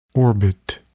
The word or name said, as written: orbit